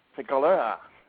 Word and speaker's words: Figueroa: Let me just say that the name is, in Jamaica, it's pronounced Figaro, and in the Spanish-speaking world, it's pronounced Figueroa. – Okay. Figueroa.